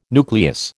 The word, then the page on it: nucleus: American English